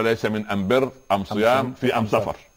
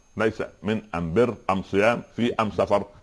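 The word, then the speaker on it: ليس من امبر امصيام في امسفر: ليس من انبر ام صيام في ام سفر. هذه لا، لكن هذه ليست لهجه قريش، لكن ثقيل على الاذن.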